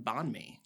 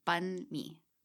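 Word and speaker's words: bánh mì: Now, until I heard you practicing pronunciation of this, I would have said bon mi. – Yes, that is incorrect. – Incorrect. – It is bun mi.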